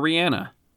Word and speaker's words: Rihanna: Or Rihanna.